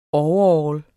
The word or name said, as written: overall